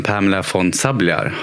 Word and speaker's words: Pamela von Sabljar: Då får jag varmt välkomna till Lyckopodden Pamela von Sabljar. Har du uttalat det rätt? – Det är helt rätt.